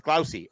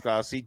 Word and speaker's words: Glausi: It's Glausi. At least I think it's pronounced Glausi. G-L-A-U-S-I.